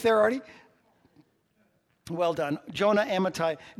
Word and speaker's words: Amittai: Jonah, son of Amittai. Well done. You pronounced that well.